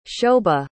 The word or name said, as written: shobha